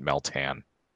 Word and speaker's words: Meltan: Yeah, they, they in the uh, introduction video they seem to pronounce it Meltan.